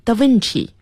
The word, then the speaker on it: da Vinci: He was called, 那么大家很好奇他的名字 ,Leonardo da Vinci, 要注意发音 ,da Vinci, 因为是意大利语。